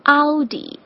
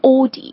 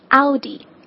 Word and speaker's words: Audi: 咱们中文的这个发音其实是非常接近原本发音的，就是 Audi。那反而是很多英国人会发成 Audi，这个是错误的哈。正确发音就是咱们的发音 Audi。